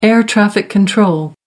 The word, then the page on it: air traffic control: American English